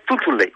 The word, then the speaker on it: Tuntunle: Tuntunle, voilà. C'est comme ça que ça se prononce, les villages heureux, Tuntunle, le village.